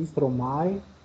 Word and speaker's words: Stromae: Tem Moderati, Stromae, é assim que se pronuncia, Stromae, Stromae.